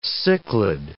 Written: cichlid

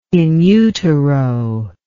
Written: in utero

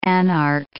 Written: anarch

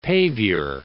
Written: paviour

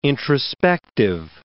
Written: introspective

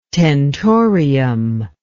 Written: tentorium

